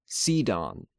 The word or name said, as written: Sidon